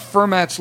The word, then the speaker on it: Fermat: Now, I'm talking about Fermat's Last Theorem.